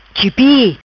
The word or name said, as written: Chipi